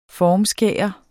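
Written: FORMSKÆRE

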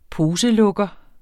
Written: poselukker

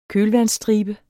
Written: kølvandsstribe

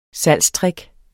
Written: salgstrick

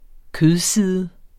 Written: kødside

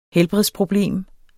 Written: helbredsproblem

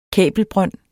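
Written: kabelbrønd

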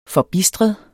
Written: forbistret

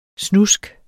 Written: snusk

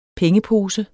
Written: pengepose

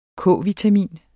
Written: K-vitamin

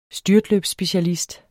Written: STYRT

